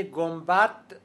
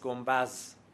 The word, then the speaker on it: گنبد: کلمه گنبد تلفظ رسمیش با داله، تلفظ فصیحش، تلفظ گفتاری و عامیانش با زس، گنبز.